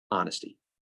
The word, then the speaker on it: honesty: And of those four H's, only one begins with a silent H, and it's the one you can't be silent about, honesty.